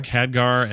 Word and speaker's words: Khadgar: Khadgar and Medivh, of course, and Lothar serving as leads.